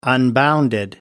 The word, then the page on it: unbounded: American English